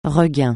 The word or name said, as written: regain